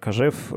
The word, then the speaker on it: Кожев: Александр Кожев, русско-французский мыслитель, эмигрант, отсюда такое ударение в этой фамилии.